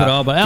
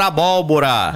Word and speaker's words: abóbora: Os locutores falando errado, chamando abroba. Porque tem gente, né, que fica atento só a esses pequenos detalhes, né? – É. – Dizer que nós sabemos já pronunciar abóbora.